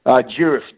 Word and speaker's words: Durif: Juriff, D-U-R-I-F.